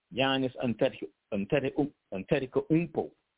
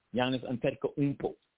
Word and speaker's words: Giannis Antetokounmpo: Let me see if I can try to pronounce it like the brothers would say. Giannis Antetokounmpo. Giannis Antetokounmpo would be something like how they would pronounce it in the context, because the U is like two O's.